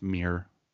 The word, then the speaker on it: Muir: And with that, a few years down the road, she founds the Muir Research Institute. Is that how you say it? – It's just pronounced Muir. – Muir.